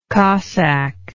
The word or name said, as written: cossack